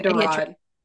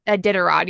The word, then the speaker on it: Iditarod: During this time, the only link to the rest of the world was the gosh, I always say that wrong. Iditarod. – Iditarod. – Yeah, I always get the T's.